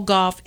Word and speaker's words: Gauff: Well, it was a big day in Wimbledon for the two of the biggest names in women's tennis, Coco Gauff and Naomi Osaka. – Am I saying that right? – Yeah. Gauff